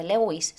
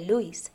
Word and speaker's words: Lewis: La, relación con el autor, el famoso autor que todos conocemos, Lewis Carroll, que, que se escribe Lewis, pero no se dice Lewis, se dice Lewis.